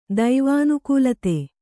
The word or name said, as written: ದೈವಾನುಕೂಲತೆ